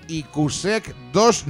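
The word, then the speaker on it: IQSEC2: IQSEC 2, no sé si lo he dicho bien, corregidme vosotros, chicos. – Sí, eso.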